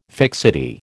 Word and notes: fixity: American English

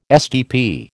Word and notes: SDP: American English

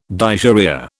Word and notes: dysuria: British English

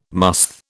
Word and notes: musth: British English

